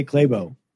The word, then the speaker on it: Clabough: I would understand it if I pronounce it because I say Clabo, but that's why I don't even say it anymore. I just say C-L-A-B-O-U-G-H and somehow they just C-L-A always follows by a Y, I guess to most people.